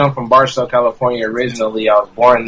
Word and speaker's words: Barstow: We got the dude out of Bartow, California. We're going to talk a lot about what the thing, did I say that wrong? Barstow? There you go. Barstow, California.